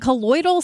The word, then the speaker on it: colloidal: Is it colloidal silver? Am I saying that correctly? – You are saying it correctly, but I think that's just like poison.